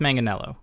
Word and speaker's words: Manganiello: Yeah, he says Manganiello.